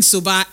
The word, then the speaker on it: Soba: This one here says, good evening, Soba. S-O-B-A. S-O-B-A.